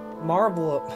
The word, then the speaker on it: Marlboro: Marlboro. The L is silent? – That's how I do it.